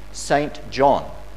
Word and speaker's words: St. John: Harold St. John, his last name is spelt capital S, T, full stop, finger space, capital J, O, H, N, St. John. But his name was pronounced St. John.